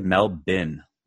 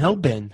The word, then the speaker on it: Melbourne: They say Melbourne. – Like Melbourne, huh? – Okay.